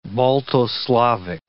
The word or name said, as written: Balto-Slavic